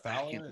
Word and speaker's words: Fowler: Uh, uh, Jermaine Fowler, is that how you pronounce it? – Yeah, he was in that movie with Lakeith Stanfield.